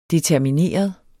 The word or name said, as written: determineret